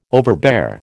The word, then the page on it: overbear: American English